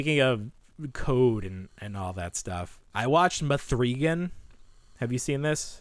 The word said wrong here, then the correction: M3GAN: I mean, Megan, right? – Oh, is that how you say it? It's not Mithrigan? Because it's written with a three.